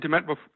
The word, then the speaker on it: Demant: Og William, jeg kan ikke udtale det, William Demant. – Sorry, den ser også flot ud. – Det var væk fra Danmark, altså lige så. William Demant var godt, eller skidt? – Ja, godt. – Ja, William Demant var godt.